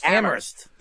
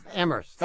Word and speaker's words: Amherst: Right, don't say the H. – Amherst. Oh, did I say it wrong? – Yeah, well, if you say the H, they'll know you're a tourist and they'll give you the more expensive menus.